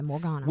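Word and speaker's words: Morgane: How do you say that? Morgana? – Chris Stapleton's wife. – Yeah, Morgana.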